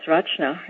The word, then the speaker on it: Rachna: One way to remember how to pronounce Rachna is to remember that it's ch as in children and that I'm a children's author. It's also ch as in chocolate, and I love chocolate. The only pronunciation I really don't like is Rachna, because it sounds like you're coughing up a furball.